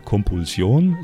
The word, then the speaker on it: Compulsion: Das ist äh, Compulsion, so spricht man in Spanisch aus.